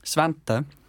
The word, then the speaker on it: Svante: Svante. – Svante. How do you spell that? – S-V-A-N-T.